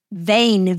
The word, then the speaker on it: vein: And then the tricky part of English is there are these inconsistent vowel patterns, like the word vein, V-E-I-N. Who would have thought that's a long A sound? Well, it is.